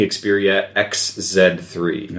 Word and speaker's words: Xperia XZ3: It's the Sony Xperia XZ3.